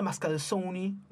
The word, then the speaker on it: Mascalzone: Faust, como Mascalzone. – No. – Que él lo pronuncia Mascalzoni, ¿no?